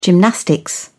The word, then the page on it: gymnastics: British English